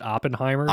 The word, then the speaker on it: Oppenheimer: Is it Oppenheimer or Oppenheimer? I'm constantly struggling with this. – The vast majority of people pronounce it Oppenheimer. – Oppenheimer, I think there might be some reason to think that Oppenheimer is also acceptable, but Oppenheimer is what I would go with.